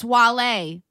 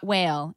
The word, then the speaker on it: Wale: It's Wale, not Whale, and I don't appreciate that.